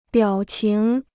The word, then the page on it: 表情: biǎo qíng